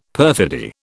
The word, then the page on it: perfidy: British English